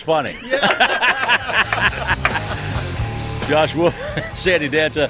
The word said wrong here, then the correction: Danto: Pronouncing that right, ain't I? – Sandy Danto. – Danto, I'm sorry.